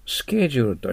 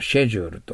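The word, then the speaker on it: schedule: 次はスケジュールとシェジュールと発音が 2 つありますよね。エマさんはイギリス人ですからシェジュールと言われます。で、ハワードはですね、スケジュールと言うと思いますよね。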